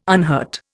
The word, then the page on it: unhurt: Indian